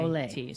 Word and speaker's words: au lait: L-A-I-T. – Olay.